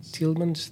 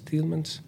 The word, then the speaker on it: Thielemans: I also heard something like Toots Thielemans, the harmonica player. Is that how you say his name? Toots Thielemans. – Thielemans.